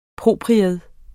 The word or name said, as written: PROPRIETS